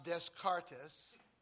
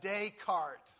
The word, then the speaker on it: Descartes: That's Descartes. Not pronounced Descartes. But Descartes, okay? You don't want to look like a fool when you pronounce his name. Descartes. He's a Frenchman. Usually in pronouncing French words, you just skip the last section. It's usually always silent.